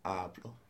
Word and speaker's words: hablo: So, in yo and tú, the accent is actually on the first syllable. You probably never noticed, have you? – Yeah, good for me. – Yeah, so yo hablo. You don't say yo hablo. – No. – Yo hablo. And tú hablas.